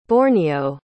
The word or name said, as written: borneo